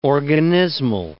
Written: organismal

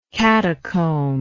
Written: catacumbal

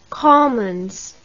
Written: colmans

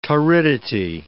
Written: torridity

